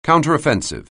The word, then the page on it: counteroffensive: anglais